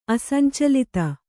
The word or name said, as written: ಅಸಂಚಲಿತ